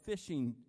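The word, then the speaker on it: Phishing: The main church is a place called Miracle Fishing CBC Church. It's pronounced fishing, but it's P-H-I-S-H-I-N-G.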